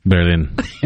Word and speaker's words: Berlin: How do you say that? – Berlin.